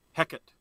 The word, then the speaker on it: Hecate: So I will mention, how do you say it? Is it Hecate? I always say it wrong. – Yeah. Hecate.